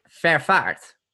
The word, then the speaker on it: Vervaart: So the reason I'm holding it back is not because I don't want to share it, but it's a very Dutch last name. So no one can pronounce it. It's Vervaart.